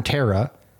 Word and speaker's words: tarot: I did not know that you learned tarot Tara? Is it Tara or Taro? – Taro. – Taro. – Taro? – Silent T at the end.